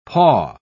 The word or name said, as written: paw